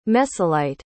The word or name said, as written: messelite